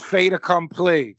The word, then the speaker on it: fait accompli: Fait Accompli. – It's close. It's Fait Accompli.